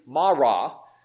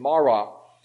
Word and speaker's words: Marah: I think it's pronounced, thinking back to my study earlier in the week, Marah. Those Hebrew words are hard for me to pronounce, but that's the typical pronunciation, Marah.